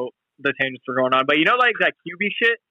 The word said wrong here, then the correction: Quibi: I thought it was Quibi. – It's Quibi. Okay, I was saying it wrong,